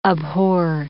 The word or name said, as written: abhor